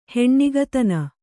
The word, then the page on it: ಹೆಣ್ಣಿಗತನ: heṇṇigatana